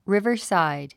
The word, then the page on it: riverside: rívərsàid; リバァサァイド